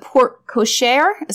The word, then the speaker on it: porte-cochere: Some designs have been added to the porte-cochere. Is that how you say it? – Mm-hmm.